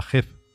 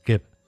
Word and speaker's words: Geb: Geb lo escribimos con G de Granada, E de España y B de Barcelona. Pero en el libro decidimos ponerle con K, o sea, Keb, porque si no, si lo mm, pronunciamos como se leería en español, sería Geb, pero no es Geb, es Gep.